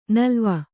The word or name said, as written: ನಲಾವು